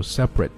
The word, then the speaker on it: separate: 特放在后面一般都不读了，这个地方是不发音的，所以就读 separate vote 还是一样特不读 separate vote，